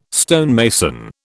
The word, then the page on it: stonemason: British English